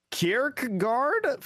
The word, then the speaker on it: Kierkegaard: Anyways, this new one that came in just this past Thursday, quality podcast from Kierkegaard five five five. I probably mispronounced no, I think that. That's correct.